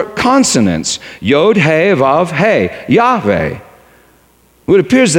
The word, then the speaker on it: Yahweh: Some used the vowels from the word Adonai, which means Lord, combined with the four consonants to produce Yehovah or Jehovah, but most scholars are now convinced that it should be pronounced Yahweh or Yahweh, Yahweh.